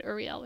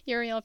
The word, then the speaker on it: Uriel: I almost said Uriel, which is the Hebrew pronunciation. Uriel corrects him, 1,214, which I like.